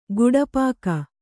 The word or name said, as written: ಗುಡಪಾಕ